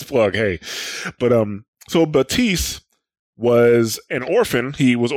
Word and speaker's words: Batiste: Not Baptiste, Batiste. Batista. And we normally pick the names of these episodes like after the fact. The name of this episode is going to be The P is Silent because it is not Baptiste, it is Batiste.